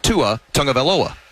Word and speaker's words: Tua Tagovailoa: The other day, we get a show together on Monday, and I couldn't say Tua Tagovailoa.